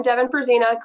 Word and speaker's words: Devin Fruzyna: I'm Devin Fruzina. Clearly, Joey, you said it right.